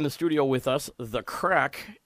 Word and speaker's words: craic: That's how it's pronounced, right? Just like a crack in the granite or a crack in the armor or exactly. – Crack in your behind or whatever it happens to be. But it's spelled C-R-A-I-C.